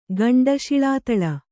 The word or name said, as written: ಗಂಡಶಿಳಾತಳ